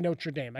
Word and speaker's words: Notre Dame: Got to say Notre Dame.